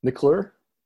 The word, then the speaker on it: McClure: McClure? – Oh, no, I did say it.